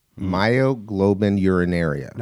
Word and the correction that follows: myoglobinuria: No, myoglobinuria. – You added two syllables.